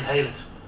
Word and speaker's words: Haylet: Chair, it's Gary Haylett. – Gary Haylett. – Haylett. How do you spell that? – H-A-Y-L-E-T.